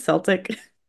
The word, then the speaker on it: Celtic: Not Celtic. It's Celtic. I feel like every time I say it wrong.